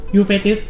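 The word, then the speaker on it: Euphrates: แล้วก็แม่น้ำยูเฟติสยูเฟติส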